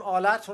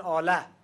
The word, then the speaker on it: آلت: مثلا ما میگیم آلت، اونها میگن آله، با ه مینویسن، با ه غیر ملفوظ.